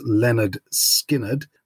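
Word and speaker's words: Lynyrd Skynyrd: Uh, we went from 1973 with pronounced Leonard Skinnard all the way through,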